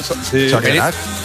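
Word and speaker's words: Zorionak: Sorionak. – Sorionak, claro. – No, Sorionak.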